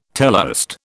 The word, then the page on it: teleost: British English